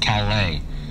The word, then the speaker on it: Calais: "First of all, it's pronounced Calais. It's French.